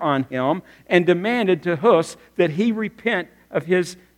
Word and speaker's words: Hus: Go with me to Czechoslovakia, and there's a gentleman there that you would call his name John Huss, but properly pronounced John Hus, and begins to see the reality in only the way that Martin Luther would explain it 100 years from this time.